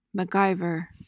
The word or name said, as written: MacGyver